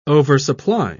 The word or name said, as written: oversupply